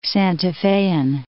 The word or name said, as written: Santa Fean